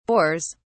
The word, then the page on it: bores: British English